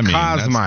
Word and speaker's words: Kosmider: I butchered that, didn't I? Kosmider. Kosmider. That's how I heard it pronounced.